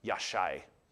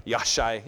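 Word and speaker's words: Yashai (ישי): And Jesse, by the way, would have been pronounced Yashai. So I don't know who anglicized that word, but it's not even close. Yashai.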